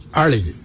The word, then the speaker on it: Ali: In our ancient language, we say Ali. It's spelled A-L-I.